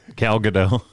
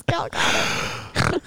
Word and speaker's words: Gadot: Gal got it. I don't know. – I mean, wrong. – She got it, but that's not how you say her name.